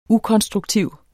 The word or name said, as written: ukonstruktiv